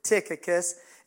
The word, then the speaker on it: Tychicus: On Wednesday, last Wednesday, I spoke on faithful to God, and I spoke about Tychicus, this man that I mispronounced his name a few times, but it's Tychicus.